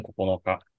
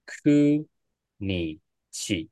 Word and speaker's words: 九日: 然后，那七跟九的话呢，不要念不啊、呃，不需要念成这个 nanoka 跟 kokonoka 啊，但是它念什么呢？这边的七跟九，你要记得念成 shichi nichi，还有 ku nichi，也就是七要念成 shichi，九要念成 ku 的意思。